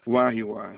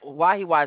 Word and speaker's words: Wahiawa: I'm going to spell it W-A-I-A-N-A-E. – Wahiwa, Hawaii. – Now, that Wahiwa is W-A-H-I-A-W-A, right? – Correct. – And then I have W-A-I-A-N-A-E. – W-A-I-A-N-A-E.